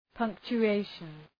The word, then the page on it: punctuation: ,pʌŋktʃʋ’eıʃən